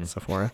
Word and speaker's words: Sephora: Sephora? – Yes. Okay. You have lots of experience with makeup. – So much experience with makeup. You have no idea. – Spend a lot of time in Sephora.